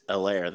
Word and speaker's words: Helaire: Edwards Helene. – Clyde Edwards Helaire, the H is silent.